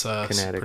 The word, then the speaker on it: Connecticut: But it's Connecticut. Pronounced without some silent letters Connecticut.